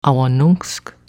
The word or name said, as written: Awanunsk